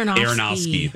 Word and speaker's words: Aronofsky: Aronofsky.